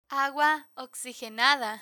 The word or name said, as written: agua oxigenada